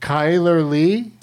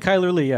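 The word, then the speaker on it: Chyler Leigh: No, first is someone named Kyler Lee. – Yeah. – Did I say it right? – You did. Kyler Lee, yeah.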